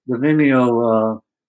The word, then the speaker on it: Vimeo: I watched it on, if I'm pronouncing it right, Vimeo.